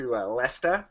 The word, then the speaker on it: Leicester: I live right next to Lesta, which is really like Leicester or something.